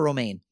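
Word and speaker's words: Romijn: On the pronunciation of her last name, what actress had to explain, quote-unquote, it's like the lettuce? – Rebecca Romaine.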